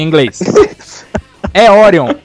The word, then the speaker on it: Orion: É Orion, cara, é a porra do nome certo, a pronúncia, filho da puta.